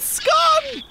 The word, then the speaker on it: scone: My scone! Scone! And yes, that's how it is pronounced, you heathens.